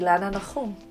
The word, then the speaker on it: Ilana Nachum: And so how do you say it to non-Hebrew speakers? – I say Ilana Nachum.